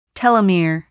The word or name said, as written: telomere